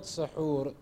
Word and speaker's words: sahur: For the word sahur, there are two ways to pronounce it. Either you say suhur or you say sahur. Suhoor is the act of having the suhoor. Sahoor is the actual food that you have at that time.